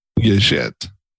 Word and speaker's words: YJIT: What did you say? – Yuh-Jit. You said it wrong.